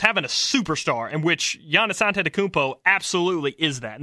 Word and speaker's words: Giannis Antetokounmpo: And yes, that is how you pronounce his name. I watched videos on videos of him pronouncing his own name. It is Giannis Antetokounmpo, the Greek Freak.